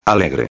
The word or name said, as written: alegre